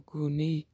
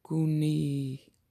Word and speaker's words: gune: Well, the Greek for wife is gune, or guni, G-U-N-E, long E, guni.